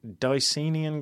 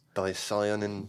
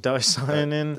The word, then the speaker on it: Dicyanin: Dicyanin. – Dicyanin. – There's so many different ways of pronouncing it, aren't there? – Yeah, I've actually heard maybe 4 or 5 different ways of saying it. But for me, it flows off the tongue saying Dicenian.